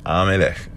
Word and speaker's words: Amalek: Amalek. That's how you actually pronounce it.